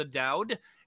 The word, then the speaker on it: Doud: You can also check out my podcast, which is Benefit of the Doud, and the last name is spelled D-O-U-D. Thank you. – That's great. – So yeah, Benefit of the D-O-U-D, and you know, you can visit Benefit of Doud on Twitter because we couldn't fit the.